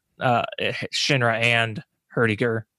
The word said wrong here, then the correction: Heidegger: Am I saying that right? Herdiger? – Heidegger.